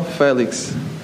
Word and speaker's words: Félix: Could you tell us how to say your name? – João Félix. – Félix? – Félix, yeah.